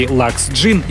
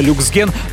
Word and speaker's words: Luxgen: Само название марки Lux Gin, если читать по буквам, то получится люксген, но в нашем представительстве предпочитают англоязычное произношение этой марки.